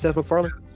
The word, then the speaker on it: Seth MacFarlane: Is it Seth MacFarlane or which one is which one did Spawn? – Yeah, it is. – It's Seth MacFarlane.